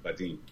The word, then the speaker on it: Vadim: Is that how you pronounce Vadim? – Yeah, Vadim.